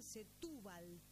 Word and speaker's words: Setúbal: espero pronunciarlo bien, Setúbal, con acento en la U, sí.